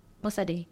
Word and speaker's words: Bosede: Faith Bosede. That's how you say your last name, right? – Yes. – Say it. – Bosede. – Oh, I like that. Yoruba.